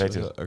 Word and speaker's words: Vassell: Vassell.